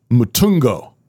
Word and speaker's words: Mutungo: Pastors and trains pastors in the community of Mutungo.